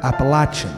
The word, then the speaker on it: Appalachian: The Appalachian Mountains, the largest mountain range in the eastern United States, which is pronounced up here, Appalachian.